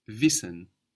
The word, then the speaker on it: wissen: To know in German is wissen, wissen. Wissen. It's spelled W I S S E N, wissen. Wissen. Like wise, like the word wise in English.